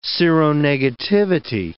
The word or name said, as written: seronegativity